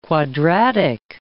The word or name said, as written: quadratic